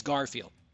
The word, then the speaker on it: Garfield: And they'll say, oh, my goodness, Michael, I've listened to you for so long, but then they get my last name wrong. It's Garfield. I am T-O-M.